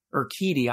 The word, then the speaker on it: Urquidy: But, you know, I would certainly rather have cool, calm, and collected Urquidy, I actually think I said it right this time without messing up,